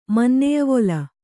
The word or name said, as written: ಮನ್ನೆಯವೊಲ